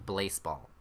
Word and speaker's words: Blaseball: So it's spelled like baseball, except after the first B, there's an L, and it's pronounced Blaseball.